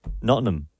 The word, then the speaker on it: Nottingham: How do you say it then? – Just like Nottingham. – Nottingham. – So that's better. It's just like, don't give it as much.